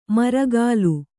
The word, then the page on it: ಮುರುಕಲು: kannada